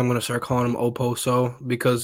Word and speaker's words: Okposo: The K in Okposo is silent, so if we've been saying Okposo this whole time, I just learned.